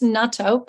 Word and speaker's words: natto: And you did pronounce natto perfectly.